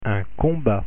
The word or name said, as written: combat